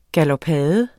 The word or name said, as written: galopade